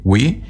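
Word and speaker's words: gui: El wi, sin acento, wi. No se olvide que la U no es muda, tiene que pronunciarlo.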